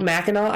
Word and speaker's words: Mackinac: It's called Mackinac Island.